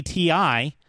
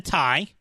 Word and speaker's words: Ti: I kept saying it's the 4070 Ti. No, no. The dude in this the, whoever whoever that is he pronounces it the tie. So it is the RTX 4070 tie.